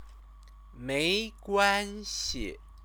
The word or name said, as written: meiguanxi